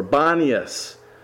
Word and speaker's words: Banias: Today it's called Banaeus, or Banias, which is the Arab pronunciation.